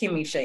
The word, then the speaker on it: Kimisha: Kimisha, yeah.